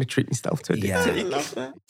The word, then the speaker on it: Diptyque: I've got Diptyque candles now. – Oh, wow. – Which, for a while, he was pronouncing as Dick Pick candles.